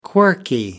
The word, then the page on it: quirky: American English